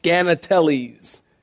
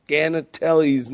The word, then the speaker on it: Skaneateles: This one is, somebody told me this is pronounced Scannateles, S-K-A-N-E-A-T-E-L-E-S. Anybody heard of that place? Me neither until I read this. Scannateles, New York.